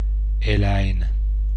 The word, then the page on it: eläin: [ˈe̞læ͡in]; /ˈe.læ.in/